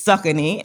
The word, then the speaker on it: Saucony: My college friend, Bima, so he would go on to lead global strategy for the shoe brand, Saucony Originals, not Saucony. I know, me too. I used to say Saucony. That is not how you pronounce it. It's Saucony. And that's the shoe brand that we know and I've known for years.